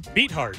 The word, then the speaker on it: Beathard: Beathard.